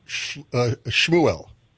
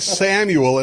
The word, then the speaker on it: Shmuel: Just to prepare you, that's pronounced Shmuel. – Shmuel. – Yes, you said, okay. you said Samuel, and it's Shmuel. I just thought I'd tell you that.